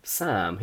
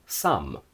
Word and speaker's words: Sam: He doesn't call me Sam, he calls me Sum, Sum, Sum, because in Italian the letter A gives a.